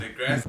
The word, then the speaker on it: deGrasse: It's Neil deGrasse Tyson, gentlemen. – Neil deGrasse. – Appropriate pronunciation. deGrasse.